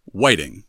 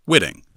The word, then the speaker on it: Whiting: Secondly, I don't know how it was said in 1641, but today, Whiting is pronounced Whiting and not Whitting, which is what we said in the episode, when referring to Whiting Ave or the family whose farm made up today's Oakdale neighborhood."